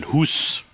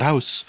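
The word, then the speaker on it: Hus: That's, by the way, how you pronounce it, Hoos, not house. – Or is it? Is it Hoos? Oh, yeah, because it's Norse, yeah.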